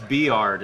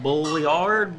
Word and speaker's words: Bulliard: It's Beard is how it's pronounced. But it's not spelled. – Fucking kidding. – If you look at it, you're like Bulliard, Bulliard.